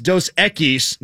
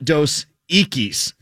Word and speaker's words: Dos Equis: Tell Joe Rokicki that the Dos Equis commercial, it's pronounced Dos Equis, not Dos Equis.